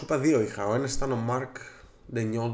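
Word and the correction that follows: Daigneault: δεν ξέρω πώ προφέρεται. – Ναι, Ντέινολτ. – Ντέινολτ, οκ. Most improved.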